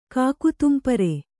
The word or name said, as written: ಕಾಕುತುಂಪರೆ